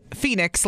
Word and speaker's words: Fenix: We got a text that said, my son's name is Phoenix, F-E-N-I-X, pronounced Phoenix like the city. – Okay. – My daughter is Tatum. T-A-Y-T-U-M.